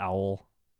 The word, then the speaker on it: awl: Really pronounce it, and it just sounds like I'm saying owl. – Owl. – Owl, you know?